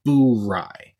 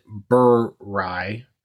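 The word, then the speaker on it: Bourye: Tonight, we are going to be drinking some High West Boo Rye. And to be clear, it is pronounced Boo Rye. I've heard a few people mistakenly pronounce it Burr Rye.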